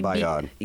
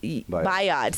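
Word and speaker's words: Bayaud: I don't really know how you say it. Bayoud? It's like Bayoud. – Bayoud. See, I knew you would know.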